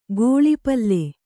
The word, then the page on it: ಗಾಲಿಪಲ್: kannada